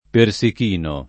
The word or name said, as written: persichino